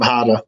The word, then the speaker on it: Harder: Olli, spelled O-double-L-I, last name Harder, Olli Harder.